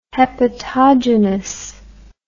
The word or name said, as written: hepatogenous